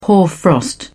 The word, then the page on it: hoarfrost: British English